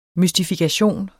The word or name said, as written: MYSTIFIKATION